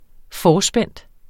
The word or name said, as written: forspændt